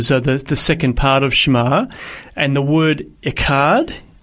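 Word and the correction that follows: echad (אחד): have I said that right? – Oh I've got to get more. Got, it needs that little in there. – echad.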